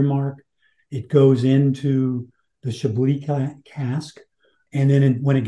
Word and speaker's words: Chablis: And I see here it's matured in Chablis wine casks. Am I saying that correctly? – Chablis wine cask at the very end.